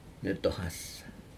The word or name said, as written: netohas